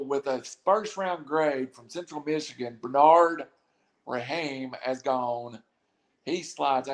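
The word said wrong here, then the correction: Raimann: I'm saying his name wrong. It's like Rayman is gone to the Colts.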